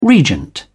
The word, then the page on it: regent: British English